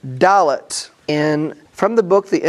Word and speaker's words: Dalet: So the fourth letter of the Hebrew alphabet, Dalet, which is pronounced Dalet, that it is a cognate with the word Delet.